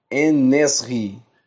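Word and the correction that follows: En-Nesyri: Ah, Enesiri.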